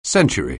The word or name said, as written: century